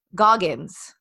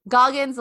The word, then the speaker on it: Goggins: I said Goggins because we had no idea how to say his last name, but he corrected me. It's Goggins. – I screamed at my radio. Goggins. Goggins like goggles.